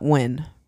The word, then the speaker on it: Nguyen: I believe you pronounce this last name Nguyen. Nguyen. You pronounce that Nguyen, right? – I think so.